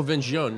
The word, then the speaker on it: Vin Jaune: Is that how you say it? – Say again? – I don't care. Vin Jaune? – Oh, Vin Jaune. Yeah, yeah, yeah.